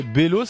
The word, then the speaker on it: Bello: Et Bello, c'est ça, Bello, Bello ? – Ouais. – Comment ça s'écrit ? – B-E-L-L-O.